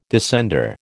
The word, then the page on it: descender: American English